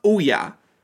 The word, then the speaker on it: Ouya: Ouya. It's pronounced Ouya.